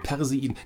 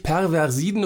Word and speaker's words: Perseiden: Ich habe es, glaube ich, tausendmal falsch ausgesprochen. Perseiden. Nicht Perversiden oder sowas.